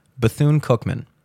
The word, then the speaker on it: Bethune-Cookman: Bethune-Cookman.